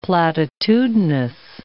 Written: platitudinous

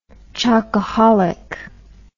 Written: chocaholic

